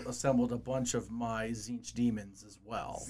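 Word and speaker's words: Tzeentch: The first T is silent. – Of course, every first T is silent, apparently. – Right. Yeah. – Yeah. – Yeah. So Zinch.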